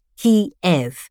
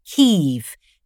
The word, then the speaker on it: Kyiv: Before the Russian invasion, the name of the capital was normally spelled in English as Kiev, pronounced Kiev, but this reflected the Russian pronunciation. After the invasion, most English-language journalists adopted the spelling K-Y-I-V, pronounced Kiev, to reflect the city's name in Ukrainian.